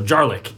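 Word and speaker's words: Djarlik: Then the next one we're going to talk about is the Jarlik, which is starts with a D, but I'm pretty sure that D is silent. So Jarlik, and this is an aquatic Xenos, which is pretty rare.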